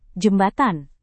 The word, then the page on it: jembatan: Indonesian